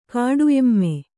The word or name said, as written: ಕಾಡುಎಮ್ಮೆ